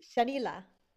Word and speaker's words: Shanila: So Shania, right? Is that how you say your name? – Shanila. – Okay. I wasn't sure like how the L played in, so I appreciate that.